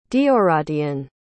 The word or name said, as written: deoradhain